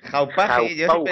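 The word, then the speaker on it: Hauppauge: Estoy hablando de la Hauspage, ¿cómo se pronuncia esto? – Hauspage Colossus.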